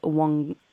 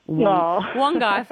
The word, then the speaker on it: Wongai: And also, I will apologise that I had been told by someone today that it's pronounced Wongee Falls, but no, it is Wongai Falls. So I do apologise for my mispronunciation there, sounding like an absolute dimwit on the radio, but no, Wongai Falls.